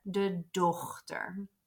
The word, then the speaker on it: dochter: And same as English has a GH sound in the word, um, we do want to pronounce that ge the G there, the dochter.